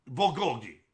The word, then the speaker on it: bulgogi: Bulgogi.